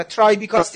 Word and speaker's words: ترایبیکا: حالا من اشتباه تلفظ کردم ترایبیکاست دیگه درسته اینا که بله، به بله.